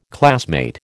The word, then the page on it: classmate: American English